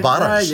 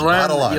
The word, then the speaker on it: Barras: You've even got the pronunciation correct. The Barras. Never mind this Barras.